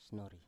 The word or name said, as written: snori